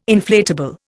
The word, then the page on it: inflatable: Indian